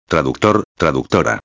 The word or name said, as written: traductor-a